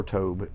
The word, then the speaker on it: Tob: Tob, or Tob, if you want to pronounce it like Job.